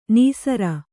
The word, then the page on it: ನಿಸ್ಸಾರ: nissāra